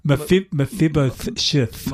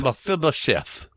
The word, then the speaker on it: Mephibosheth: I think it's pronounced Mephibopheth. – Have you written it down? Yeah, have a look. You won't be able to read my writing. Mephibosheth. – Mephibosheth.